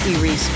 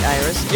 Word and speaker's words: Iris: Hi, my name is Iris Farr, spelled like Iris. – Yeah, I say Iris. – Yeah, Iris. Okay. I'm just saying. – Okay, where are you from originally? – The Bronx. – Okay, and your parents? – Israeli. – Okay, so that's Israeli pronunciation? – Yeah.